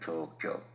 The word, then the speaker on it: Tokyo: Remember, Kyoto and Tokyo. Two syllables, not three.